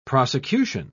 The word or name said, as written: prosecution